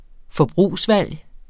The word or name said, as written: FORBRUGSVALG